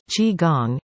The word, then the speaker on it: Qigong: Qigong, pronounced Qigong,